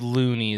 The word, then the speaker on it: Looney: Is it Looney? Am I saying that properly? – It's Looney is how you say Looney.